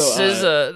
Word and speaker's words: SZA: It is SZA.